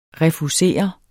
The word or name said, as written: refusere